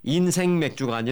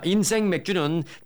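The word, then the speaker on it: 인생맥주: 인생 맥주가 아니라 인생 맥주입니다. 제가 발음을 세게 한 게 아니라 원래 이름이 그렇습니다. 자, 인생 맥주는 개점 첫날부터 부에나팍에서 방문객이 몰리며 대기줄이 길게 늘어서고 있습니다.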